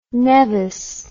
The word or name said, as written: neves